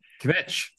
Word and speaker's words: Kvetch: Kvetch, is that how you pronounce it? – Yeah, Kvetch. That's exactly right.